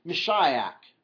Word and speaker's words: Mashiach: Well, really, when we say Messiah, we're not really saying an English word. We're saying an English pronunciation, sort of poor English pronunciation of the Hebrew word Mashiach.